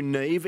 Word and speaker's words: Niamh: Uh, we call you Neve, even though your Christian name is spelled N I A M H.